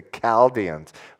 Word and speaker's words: Chaldeans: It says there, verse 17, therefore he brought against them the king of the Chaldeans? Chaldeans, okay. My wife thinks Stephanie thinks I purposely say that wrong. It's the Chaldeans,